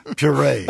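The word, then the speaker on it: purée: You pronounce the verb puree as puree. – Versus puree. – The correct French pronunciation, puree.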